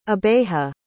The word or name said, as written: abeje